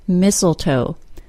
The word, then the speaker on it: mistletoe: Speaking of a silent T, there's also one in the word mistletoe, spelled M-I-S-T-L-E-T-O-E.